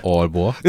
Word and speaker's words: Aalborg: Oder ist falsch ausgesprochen? – Auf Deutsch ist das richtig. – Und auf Dänisch? – Aalborg.